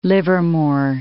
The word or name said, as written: Livermore